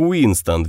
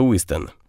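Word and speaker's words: Wystan: Хоть они и не могли выносить акцент друг друга, Маккалрос произносила его имя как Уинстон вместо Уистон.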